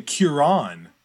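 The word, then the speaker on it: Ciarán: Kieran.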